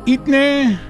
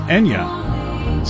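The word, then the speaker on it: Eithne: Itne is pronounced Enya. So it's not Itne.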